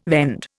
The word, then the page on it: vend: Indian